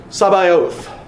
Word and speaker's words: Sabaoth: and nobody knows how to pronounce it, especially when you're younger, you finally figure out, "Lord Sabaoth."